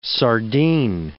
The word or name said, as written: sardine